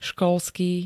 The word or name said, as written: školský